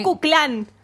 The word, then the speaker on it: Ku Klux Klan: En el Ku Klux Klan también. – Ku Klux Klan.